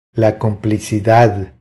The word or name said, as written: La complicidad